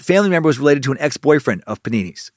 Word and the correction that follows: Papini: It keeps reminding me of the fucking sandwich of a panini. It's Papini.